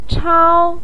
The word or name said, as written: chao1